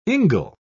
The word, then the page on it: ingle: British English